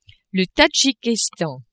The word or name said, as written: Tadjikistan